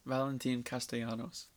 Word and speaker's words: Valentin Castellanos: I might just butcher the name, though. Valentin Castellanos? – Valentin Castellanos. – There you go. – Same. You're pretty much there.